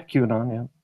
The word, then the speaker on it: QAnon: Yeah, QAnon, yeah.